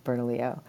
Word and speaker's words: Bernalillo: So that's like Bernalillo County, by the way, the way you should pronounce where it's coming from, Bernalillo.